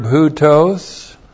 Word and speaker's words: houtos: The adverb houtos. H-O-U-T long O-S, houtos.